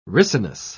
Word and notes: ricinus: British English

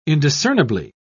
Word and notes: indiscernibly: British English

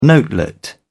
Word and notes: notelet: British English

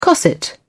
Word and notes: cosset: British English

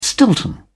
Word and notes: stilton: British English

